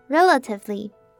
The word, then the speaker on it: relatively: 第三个字是 relatively，那 relatively 的重音在第一个音节，第一个音节的母音 e 我们要发短母音 a 的声音，第二个音节的母音 a 和上一个字 probably 一样，是发弱化母音 a，所以这个字念成 relatively。